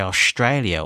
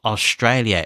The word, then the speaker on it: Australia: I mean, for example, we had a New Zealand Prime Minister for far too long who mispronounced the name Australia, and he would say Australia all the time. A lot of people do this here, and they use like a SH sound in words like Australia and strong.